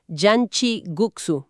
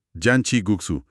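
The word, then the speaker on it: janchi guksu: La pronunciación correcta que nos indican las referencias es Yanchi Guksu, ¿verdad? – Así es, Yanchi Guksu.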